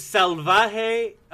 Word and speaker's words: Salvaje: It's Salvaje Apparel.